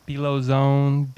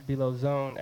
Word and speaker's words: Belo Zone: Belo Zone, B-E-L.